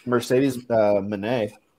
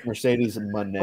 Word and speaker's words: Mercedes Moné: Oh, if they get Mercedes Moné, I think that's how it's supposed to be pronounced. Mercedes Moné.